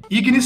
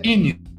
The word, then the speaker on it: Ignis: Ignis significa fogo em latim, né? A pronúncia correta seria Inis, né? Mas a gente aportuguesou.